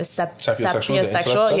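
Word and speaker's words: sapiosexual: I am a sepio... Is it sepio? Is that how you pronounce it? – Sepiosexual? – Yeah.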